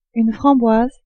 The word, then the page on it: framboise: French